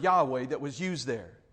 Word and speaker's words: Yahweh: The word Lord is actually from a Hebrew word that we pronounce Yahweh. I don't believe it's really supposed to be pronounced, because it's the first initials of each word. When God said, "I am that I am," the first initials of each word in Hebrew, but we'll pronounce it this way for us today because it's easier to talk about. We pronounce that word Yahweh,